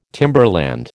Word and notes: timberland: American English

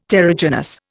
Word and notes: terrigenous: Indian

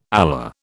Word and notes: alar: British English

